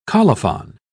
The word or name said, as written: colophon